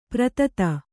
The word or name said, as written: ಪ್ರಥಿತ